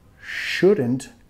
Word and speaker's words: should: Should. La L muda. Should. No es should.